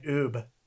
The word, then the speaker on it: Ub: I think it's Ube. – It's not Ube, because it's spelled U-B. It is a two-letter name.